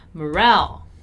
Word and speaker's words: morale: Notice how in moral, our stressed syllable is mo, moral, right? While in the first word we have there, our stressed syllable is morale.